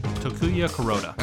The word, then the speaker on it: Takuya Kuroda: Takuya Kuroda? – Takuya Kuroda.